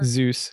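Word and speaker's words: Dzus: We lost the Zeus screw. Am I pronouncing that right? Is it Zeus? There's a D there. – Zeus.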